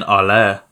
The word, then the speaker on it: Haller: Is it Haller or is it Halla? – I believe the correct pronunciation is Sebastian Haller. – Haller. See, yeah, I would have never got that. – He's French, isn't he? So he is French. Yes, it'd be Haller.